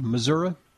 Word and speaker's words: Missouri: Am I pronouncing that correctly? Missouri? – Yes, sir, you are.